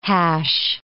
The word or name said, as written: hash